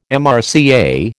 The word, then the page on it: MRCA: American English